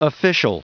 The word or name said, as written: official